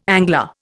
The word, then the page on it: angler: Indian